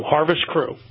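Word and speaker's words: Harvest CROO: Harvest Crow Robotics, C-R-O-O. What's the impact of that? Crew. What is it? Harvest, how do you pronounce it? – Crew. Harvest Crew. – Harvest Crew, as in C-R-O, C-R-E-W in a manner of speaking.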